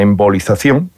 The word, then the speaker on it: embolización: La intervención la realiza un radiólogo intervencionista y consiste en esa embolización, no sé si lo digo bien, de las arterias uterinas. ¿Lo he dicho bien, Isabel? – Sí.